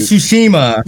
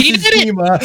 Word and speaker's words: Tsushima: Come on, Ghost of Tsushima. – No, you got it. – Ghost of Tsushima.